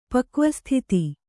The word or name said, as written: ಪಕ್ವಸ್ಥಿತಿ